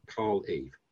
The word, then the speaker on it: Carl Eve: Okay, Carl Eve, 7 letters. C-A-R-L-E-V-E.